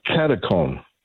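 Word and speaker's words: katechon: How do you pronounce this, and what is Katachan? – Katakon.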